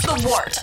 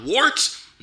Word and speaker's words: Wort: This is The Wort on Govs Radio. – It is The Wort, not warts, not forts, not short, The Wort.